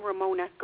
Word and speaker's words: Romona: Yes, I'm Ramona Garnines. – Ramona, R-A-M-O-N-A? – R-O-M-O-N-A.